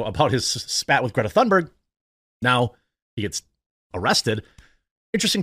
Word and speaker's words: Thunberg: By the way, with Greta, I always hear the Amy pronunciation where it's like almost Thunberg. I want to see the proper pronunciation. Greta. Because I've been saying it in a variety of ways. – We are environmental activists who have recognition for promoting the view that humanity is facing an existential crisis arising from climate change. We are looking at how to pronounce Greta Thunberg. – Thunberg! Ah! Wow, of course Amy was on point.